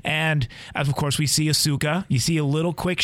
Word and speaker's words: Ahsoka: Is Ahsoka. – I think I'm saying it right. – I think you are, yeah.